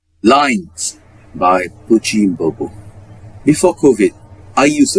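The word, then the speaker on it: Pukki: Oh, Pookie is the Norwich player. This is Pucci, not Pookie.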